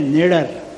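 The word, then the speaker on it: Nader: तो वहाँ उपभोक्ताओं का बड़ा संगठन जिसके प्रमुख है मिस्टर ना, उनका उच्चारण है नेडर लेकिन स्पेलिंग है NADIR।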